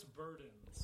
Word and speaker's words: Burdens: No, it's Chris Burdens. – Chris Burdens? – Yeah, like with an S. Like my name.